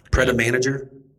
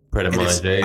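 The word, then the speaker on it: Pret A Manger: Pret-a-manager. – Pret-a-manger. – I guess it's pronounced Pret-a-manger. Somebody had said it. – That's French. Yeah.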